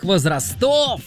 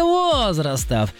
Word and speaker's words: возрастов: В Москве прошел самый массовый турнир в истории настольного тенниса, который собрал около 7000 участников разных возрастов или возрастов. Иван из Томска, выбирайте. – Возрастов. – Иван, дорогой мой человек, ударение в данном слове всегда падает только на первый слог. Поэтому правильно говорите возрастов. Возрастов.